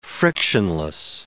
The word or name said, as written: frictionless